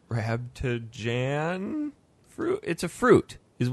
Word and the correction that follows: Rambutan: J-A-N? – Huh? – Rambutan fruit. – Like B-A-J-A-N. – Did you find it, Dan? – Yes. Is it red? – Oh, yes. How do you spell it? – Rambutan.